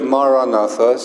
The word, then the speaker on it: Maranatha: If you choose this word, Maranatha, say it as four syllables. Ma, ra, na, tha.